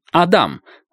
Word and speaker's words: Адам: Адам...» Кивком указал на Белобрысова. «Адам...» Ударение на втором слоге.